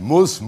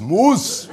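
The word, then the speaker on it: Mousse: Nicht Muss, Muss. Verstehst du? Ein bisschen französisch, oder?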